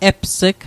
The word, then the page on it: eps'g: Mi'gmaq